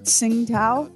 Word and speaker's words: Tsingtao: Lamar, let me make sure I say this right. It's the Chinese spirit Tsingtao. Is that how you say that? – Yes, that's it.